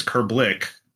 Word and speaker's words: Krblich: It's from Poland, and it's Krblich. But yeah, it's the Krblich is a common pronunciation for it, so it doesn't bother me at all.